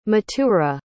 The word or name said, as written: mathura